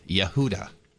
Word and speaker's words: Yehuda: Y-E-H-U-D-A, Yehuda. – I would pronounce that Yehuda.